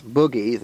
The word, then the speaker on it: boogie: But if you listen closely to John Lee Hooker or anybody else who really knows, it most certainly is boogie, the most proper Southern pronunciation of that word.